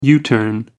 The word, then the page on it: u-turn: American English